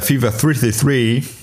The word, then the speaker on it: Fever 333: Kannst du die 333 von Fever nochmal englisch aussprechen, nur wegen der Exaktheit? – Achso, sorry. Äh, Fever 333.